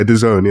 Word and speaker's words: DAZN: Is that how that's pronounced? DAZN? – Yeah, DAZN, yeah.